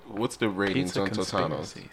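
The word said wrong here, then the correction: Totonno's: So both Totano's, I'm probably saying that wrong. It's Totonno.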